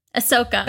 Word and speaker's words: Ahsoka: Wait, is that, am I saying her name right? Ahsoka? – Ahsoka.